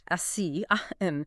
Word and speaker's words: ASEAN: We're seeing the Southeast Asian digital economy that's ASEAN, pronounced ASEAN, likely to blow past $300 billion in GMV, gross merchandise value, in 2025.